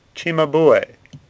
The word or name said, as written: Cimabue